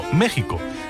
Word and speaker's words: México: Bueno, lo que está claro es que México se pronuncia México en castellano y no México, que a veces se oye. Aunque cierto es que es más habitual escuchar Texas cuando debería ser Texas.